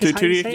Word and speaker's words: Tudyk: Alan Tudyk, I think. Tudyk. – Yeah, that's it.